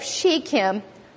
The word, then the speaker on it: Shechem: The King James says Sychem. There's different ways to pronounce this, but that's it. To the place of Shechem, unto the plain of Moreh.